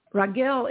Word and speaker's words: Raguel: The Archangel Raguel, I always want to spell it, pronounce it the way it looks. It's R-A-G-U-E-L, but it's pronounced Raguel.